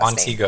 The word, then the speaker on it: Montego: How do you pronounce that last Montego. – Name? Montego. Oh, like Montego. – Like the Montego Bay in Jamaica.